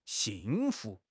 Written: xing4 fu2

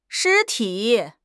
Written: 尸体

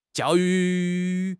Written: jiao4 yu4

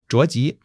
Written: zhao2 ji2

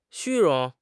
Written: 虚荣

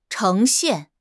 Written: 呈现

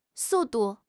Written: su4 du4

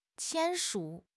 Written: 签署

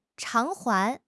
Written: 偿还

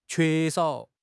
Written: que1 shao3

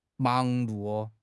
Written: 忙碌

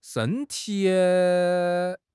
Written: shen1 ti3

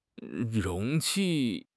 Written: 容器

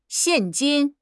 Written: xian4 jin1

